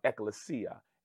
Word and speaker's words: ecclesia: Some people say ecclesia. Uh, uh, most theologians believe it's properly uh, pronounced um, ecclesia.